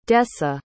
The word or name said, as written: desa